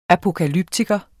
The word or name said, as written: apokalyptiker